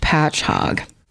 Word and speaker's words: Patchogue: And in parentheses, it does say pronounced Patchogue.